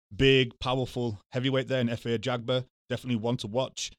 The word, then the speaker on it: Efe Ajagba: And then also I always have trouble pronouncing his name. – Efe Ajagba. – Ajagba goes up against Ali Aaron Demirizin.